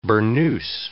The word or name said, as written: burnous